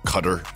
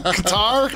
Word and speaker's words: Qatar: It's Q-A-T-A-R. – Yeah. – It's Qatar. – It's Cutter. – It's Qatar. – So some producer find the pronunciation of this country it's that Qatar. We are going to. – Doha. Qatar. – Cutter. It's actually Cutter.